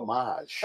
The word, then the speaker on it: homage: Like, what English guy sat there and said, no, it's spelled, it looks like homage. – Yeah. – But we're going to say homage because we want to sound like douchebags.